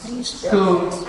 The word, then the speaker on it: Scone: Scone. – And it'll say stone, yes. It's S-C-O-N-E. But it's pronounced Scone.